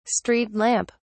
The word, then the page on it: street lamp: British English